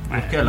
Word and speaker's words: Urquell: Urquell, la pronuncio bene? – Sì, è il nome di una, cioè, prodotta proprio a Pilsen.